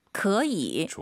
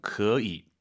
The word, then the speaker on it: 可以: 可以. – 좋아요. 삼성이 두 개니까 앞에 可, 그 다음 반삼성. 可以. 뚝 떨어지는 센스 보여주시고요.